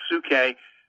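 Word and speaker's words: psyche: It comes from P-S-Y. See, we get psychology from it. It's pronounced suke, but it has to do with the soul.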